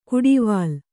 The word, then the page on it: ಕುಡಿವಾಲ: kuḍivāla